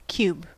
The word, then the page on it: cube: American English